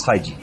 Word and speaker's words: Feige: Ya los oí decirlo, es Feige.